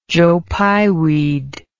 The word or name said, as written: joe pye weed